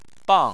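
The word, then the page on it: bang: tone 4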